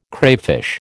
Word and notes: crayfish: American English

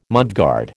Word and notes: mudguard: American English

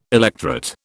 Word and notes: electret: British English

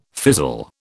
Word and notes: fizzle: British English

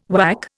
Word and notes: WAC: Indian

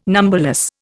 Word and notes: numberless: Indian

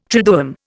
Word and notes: triduum: Indian

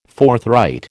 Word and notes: forthright: American English

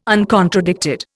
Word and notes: uncontradicted: Indian

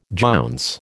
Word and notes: jounce: American English

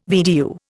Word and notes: VDU: Indian

